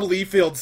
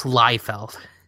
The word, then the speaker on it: Liefeld: In the ongoing sub-series, it's pronounced Liefeld.